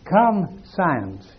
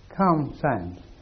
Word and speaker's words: conscience: Would you mind pronouncing it down your nose like a true French girl, please? The one that we say conscience in English. Yes, conscience. Well, actually, conscience, yes? Conscience.